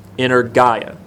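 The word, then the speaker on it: energeia: And it's the word in Greek, in ergaia, inner Gaia. Inner Gaia, which, if you write it out, it's E-N-E-R-G-E-I-A. Looks like energia, but it's pronounced inner Gaia.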